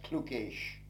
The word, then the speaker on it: Clucas: Clucas, C-L-U-C-A-S, is a good old Gaelic name. It was spelt C-L-U-G-A-A-S-H, and is often pronounced even in these days as Clucaish or MacClucaish, and means son of Lucas or Luke. Bridson, B-R-I-D-S-O-N, was spelt B-R-I-D-E-S-O-N, the son of Bride, or Bridget's devotee.